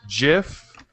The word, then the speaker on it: GIF: And the dictionary has two pronunciations, GIF and GIF. And the creator says, the dictionary is wrong. There's only one way to say it, GIF. With a J.